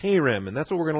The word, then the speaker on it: Cherem: C-H-E, if you want to put a line over the E, you can. That first E is a long E. R-E-M, the second E is a short E. And so we pronounce it Cherem, number 2764.